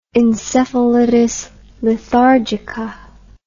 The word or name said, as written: encephalitis lethargica